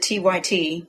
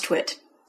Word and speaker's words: TWiT: JAWS and other screen readers might pronounce it T-Y-T because the T's are capitalized, but it's really pronounced TWiT.